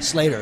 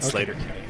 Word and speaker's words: Sleater-Kinney: Slater. – Okay. Slater Kinney. – Even though it's spelled Sleater.